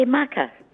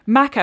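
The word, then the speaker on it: Makar: Maka. – Maka.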